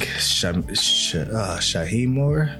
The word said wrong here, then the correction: Shameik Moore: I want to get his name right. He also was, I think he got his first, like, Shameik Moore.